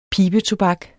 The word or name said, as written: pibetobak